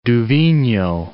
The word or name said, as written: Du Vigneaud